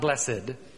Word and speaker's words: blessed: And understand, it's blessed, not blessed. It's pronounced blessed.